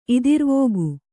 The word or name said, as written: ಇದಿರ್ವೋಗು